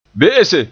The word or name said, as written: be'ese